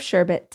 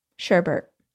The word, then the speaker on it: sherbet: thank you for wearing the color of sherbet today. Sherbet? Sherbert. I grew up saying sherbert, but it's spelled sherbet.